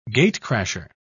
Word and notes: gatecrasher: British English

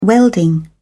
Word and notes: welding: British English

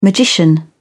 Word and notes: magician: British English

